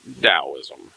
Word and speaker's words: Taoism: Don't let that T confuse you, folks. That is pronounced Taoism.